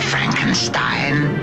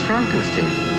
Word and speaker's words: Frankenstein: Dr. Frankenstein. – It's pronounced Frankenstein. You must be Igor.